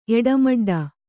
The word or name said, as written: ಎಡಮಡ್ಡ